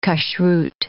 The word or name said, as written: kashrut